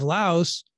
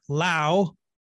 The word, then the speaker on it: Laos: In fact, a recent study out of Laos, actually, someone just told me you're supposed to call it Lao. The S is silent.